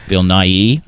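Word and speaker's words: Bill Nighy: Bill Nye, I guess. Yeah, that's how you say his name.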